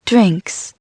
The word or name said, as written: drinks